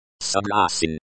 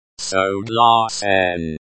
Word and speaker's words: согласен: Согласен. Согласен. Stress on the second syllable.